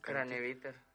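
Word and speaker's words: Kranevitter: Eh, Matías Cavetiner, no sé cómo se pronuncia. – Craneviter.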